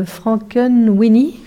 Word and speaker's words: Frankenweenie: Alors, on écoute Frankenweenie, comme ça, c'est comme ça qu'on prononce.